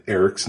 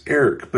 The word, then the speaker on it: Airk: I wasn't going to go on a tirade about how everyone on the show, except for uh, Warwick Davis got Eric's name wrong. It's not Eric, it's Airk.